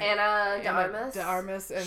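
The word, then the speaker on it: Ana de Armas: Anna de Armas.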